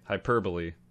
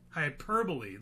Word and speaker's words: hyperbole: And I know I'm prone to hyperbola. Hyperbole? Did I say it right? – Hyperbole. – Hyperbole. There we go.